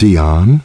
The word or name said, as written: Dion